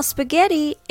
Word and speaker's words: spaghetti: Now, spaghetti is an Italian word, but in English it is pronounced with English accent and not with Italian accent. So it sounds like this spaghetti. The rhythm goes da da da.